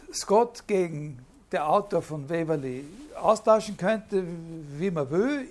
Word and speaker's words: Waverley: Ja, die waren Zeitgenossen und Waverley, angeblich ist die richtige Aussprache von Waverley, aber ich bleibe bei Waverley, ob Sir Walter Scott der Autor von dem Roman Waverley ist.